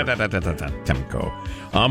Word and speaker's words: Antetokounmpo: Giannis Antetokounmpo. I can't say his name ever. – Antetokounmpo.